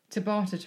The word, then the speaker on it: Tabata: No, Tabata training. Am I saying it wrong? – No, that's it.